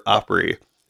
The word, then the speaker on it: Opry: It says word origin and history for Opry, 1914 U.S. dialectal pronunciation of opera.